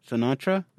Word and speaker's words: Sinatra: Repeat after me. Sin. – Sin. – Ah. – Ah. – Tra. – Tra. – Sinatra. – Sinatra? – There you go.